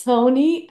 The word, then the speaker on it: Sony: But SUNY Pictures is going to produce it, and they already casted Daisy Edgar Jones as Carol. – Wait a minute, did you just pronounce Sony as Suni Pictures? – Oh my God.